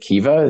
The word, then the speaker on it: Kiva: And you selected, forgive me if I mispronounce it, Kiva, is that right? K-I-V-A? Mm-hmm. Yeah. – That's right.